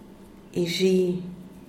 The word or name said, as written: ixhi